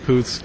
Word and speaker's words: Poots: We've always called it Poots, because it rhymes with boots, so mm-hmm.